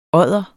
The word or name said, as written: odder